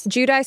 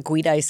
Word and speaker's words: Giudice: Is Teresa, how do you say her last name? – Giudice still in prison? – It is? That's how you say it? I always wanted to say like Giudice because it's like they're Italian, right? – No, she's been out since 2015.